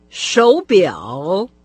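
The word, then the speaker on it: шоу бяо (手表): По общему правилу, третий тон, с которым произносится «шоу», перед «бяо» меняется на второй. – «Шоу бяо», «шоу бяо», «шоу бяо».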